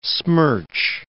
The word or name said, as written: smirch